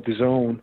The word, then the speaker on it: DAZN: And thank God, praise God, that, you know, DAZN, that's how they pronounce it, DAZN took a notice to what it is that we do.